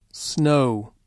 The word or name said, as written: snow